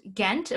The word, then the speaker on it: Ghent: Ghent.